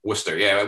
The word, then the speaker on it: Worcester: Worcester, Mass. – Yeah. Worcester.